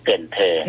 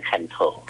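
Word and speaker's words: Cần Thơ: เกินเธอถ้าอ่านตามภาษาอังกฤษจะอ่านว่าคันโถอ่านตามภาษาอังกฤษจะอ่านว่าคันโถ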